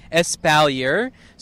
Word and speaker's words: espalier: So it's E-S-P-A-L-I-E-R. Espalier. – Espalier. – It's a French word. – Yeah, it's a French word. So it's pronounced espalier. I don't speak French, so that's the best of my ability, but spelled with an L.